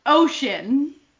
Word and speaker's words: 5TION: Their name is spelled the number 5, T-I-O-N. So. – 5 T-I-O-N. – Yeah. – 5-shen? Fiction? Oh, my goodness. – Okay, their name is pronounced Ocean because the Korean 5 is O.